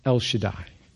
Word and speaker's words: El Shaddai: And in the Hebrew language, that word is pronounced El Shaddai.